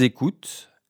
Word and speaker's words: écoutent: Ils écoutent. Elles écoutent. Je ne prononce pas ENT final.